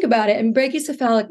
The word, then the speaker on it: brachycephalic: Yeah, brachycephalic.